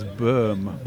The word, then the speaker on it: Böhm: And then after that, a French reporter, played by Karl Heinz Böhm, I never know how to say his name, the Austrian actor. – Yeah, I think it's Böhm. – Böhm, kind of okay. Böhm.